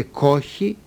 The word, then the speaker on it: κόχη: Λεγόταν κόγχη, με γάμαχη, κόγχη, στην αρχαία Ελλάδα. Λέγεται κόχη χωρίς γάμα σήμερα. Το κό βέβαια όμικρον και στη μια και στην άλλη περίπτωση.